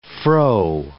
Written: fro

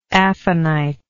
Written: aphanite

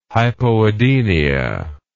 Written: hypoadenia

